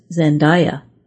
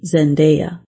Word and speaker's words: Zendaya: The name of the actress Zendaya is often mispronounced as Zendaya, but it should be Zendaya.